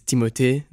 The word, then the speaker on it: Timothée: So the way it was meant to be pronounced is Timothée. That's just the way it's spelled, but mm-hmm. that seems a lot to ask of people, particularly people with American palates.